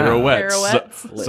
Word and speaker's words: pirouettes: Pirouettes. – So he actually pronounced. So he actually pronounced it correctly, stupid. And he just didn't even know it.